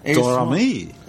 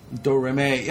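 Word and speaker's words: Domrémy: So if you're pronouncing French, basically it's all phonetic, but ham it up. – Okay, so the family lived in Dormany. – Dormany. – Sure, perfect. – Dormany. – Dormany. Yeah, that's right.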